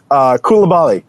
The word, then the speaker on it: Koulibaly: Koulibaly. For 70 million euros or pounds, I don't know. Complete BS. – Say his name again because I interrupted you rudely. – Oh, no worries. Koulibaly.